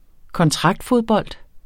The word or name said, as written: kontraktfodbold